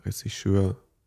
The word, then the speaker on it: Regisseur: Wie, wie kann ich mir das vorstellen, beschreibt dann da zum Beispiel der Regisseur ähm, äh, wie spricht man es auf? Ressi? – Regisseur.